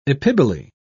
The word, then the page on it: epiboly: British English